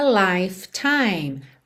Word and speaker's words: lifetime: And last for a lifetime. Lifetime. Percebe que tem dois E's aí que não são pronunciados.